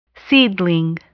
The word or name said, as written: seedling